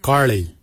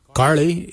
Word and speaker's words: Giilyi: Darb is spelled D-H-A-A-B. It is a traditional knife, a darb. For boomerang, we say kairi. Kairi is the traditional name for boomerang. Kairi is spelled G-I-I-L-Y-I, kairi. For the traditional stone axe, we say koch, koch. Koch is spelled G-O-O-D-J, koch.